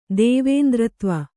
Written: ದೇವೇಂದ್ರತ್ವ

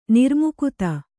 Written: ನಿರ್ಮುಕುತ

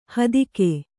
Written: ಹೆತ್ತೇಗ

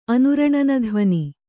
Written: ಅನುರಣನಧ್ವನಿ

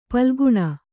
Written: ಪಾಲಘ್ನ

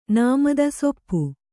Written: ಸೂಪ

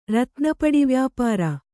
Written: ರತ್ನಪಡಿವ್ಯಾಪಾರ

